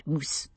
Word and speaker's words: mouse: Being Scottish, she pronounced it moose, which made Bobby laugh and choke over his muffin.